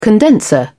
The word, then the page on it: condenser: British English